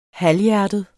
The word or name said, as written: halvhjertet